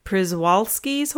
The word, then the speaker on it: Przewalski: And the Przewalski's horse.